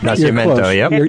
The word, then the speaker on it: Nacimiento: And we have 20 different, Lake Nyep down there is one of our sister lakes, Lake Nacimiento, correct? Am I pronouncing that correct? – Nacimiento, That's your area, not mine.